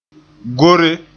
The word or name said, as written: gore